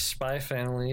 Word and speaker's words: Spy x Family: It's Spy Family. You don't pronounce the X the same way you don't pronounce the X in Hunter Hunter.